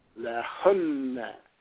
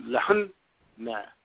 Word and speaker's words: lahunna: Now, mind you that in terms of pronunciation of Arabic words, remember this, that when you have a letter that has shadda and you double the letter, the first of the doubled letters, you must give it an abrupt stop. So notice I said lahunna, lahunna. So it's emphasis on the first of the doubled letters, and then you smooth it out on the second letter, lahunna.